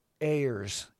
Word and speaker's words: Ayers: Joe Ayers, A-Y-E-R-S, I think it was how he pronounced it, and his wife Hazel live on the East Fork Road in Sula.